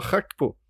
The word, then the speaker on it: Gakpo: Han är holländare. Det ska vara Schackpo.